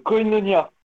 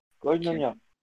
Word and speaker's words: Koinonia: Koinonia. – Tu peux me les plaît? – Alors, ça s'écrit K-O-I-N-O-N-I-A. Koinonia. – O-I. – Koinonia.